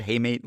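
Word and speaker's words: hamate: Yeah, that's how you say it, haymate, like the little wrist bone where it can just look like a regular swing, but they break a bone.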